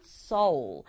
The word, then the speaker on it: Seoul: 서울을 영어로 써서 발음을 하면 Seoul. L 소리를 살려서 이렇게 Seoul 하고 발음하게 돼요.